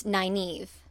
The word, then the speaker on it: Nynaeve: And I've watched all the way through twice, so I now know that it's Nynaeve instead.